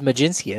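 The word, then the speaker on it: Mlodzinski: But have been tied to both Cade Cavalli out of Oklahoma, John Gray's old stomping grounds, and Carmen Majinski. – You nailed it. It is Majinski. That's right.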